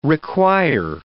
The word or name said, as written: require